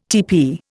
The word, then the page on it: teepee: Indian